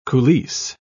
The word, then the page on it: coulisse: British English